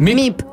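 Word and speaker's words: Miep: y esa mujer se llamaba Maip Jis. ¿Cómo le pronunciamos? – Mip. Me dijeron recién desde Países Bajos. – En la serie lo dicen Mip.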